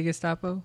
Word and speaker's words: Gestapo: How do you say Gestapo? – Gestapo.